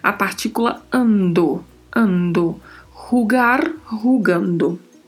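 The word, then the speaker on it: jugando: E a particularidade de pronúncia que temos aí é a primeira letra da palavra a primeira letra, que é a letra J. J. Pronuncia-se assim, com este som, que nem é um R como o R do português, não tem um som vibrato, mas gera este som. Rugar, rugando. Em, que é uma preposição, e ele, que é o artigo masculino no singular.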